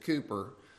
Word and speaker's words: Cowper: It looks like Cowper if you look him up, but they say you pronounce it Cooper.